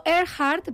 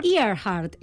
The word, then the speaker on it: Earhart: Bueno, yo digo Earhart, pero también he oído Earhart. Es que claro, esto pues en fin, depende del acento pulido que tenga cada cual.